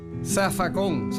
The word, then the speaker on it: zafacón: Zafacón, se escribe con Z, pero se dice con S en puertorriqueño.